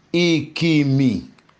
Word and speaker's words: ikimi: Ikimi. Ikimi. Eyegburo for me taren. Toshe. These are three syllables, which are I-ki-mi, I-ki-mi, I-ki-mi.